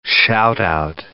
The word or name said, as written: shout-out